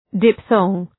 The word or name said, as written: diphthong